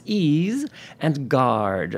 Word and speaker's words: Isengart: But I always say, you know, my name is pronounced ease and guard.